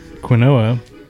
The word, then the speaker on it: quinoa: Hemp, quinoa, oysters. – Quinoa. – Oh, that's how you pronounce that? – Hey, I say...